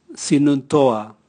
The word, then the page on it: sinun toa: Raga